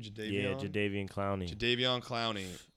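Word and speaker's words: Jadeveon Clowney: Jadavion Clowney.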